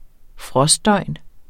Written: frostdøgn

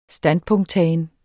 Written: standpunkttagen

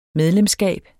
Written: medlemskab

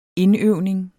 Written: indøvning

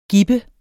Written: gib

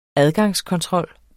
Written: adgangskontrol

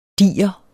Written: diger

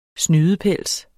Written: snydepels

